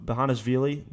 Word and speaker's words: Bezhanishvili: I think Giorgi Benishvili is one of those guys uh, who, Bezhanishvili, that, that's how you pronounce it, Bezhanishvili.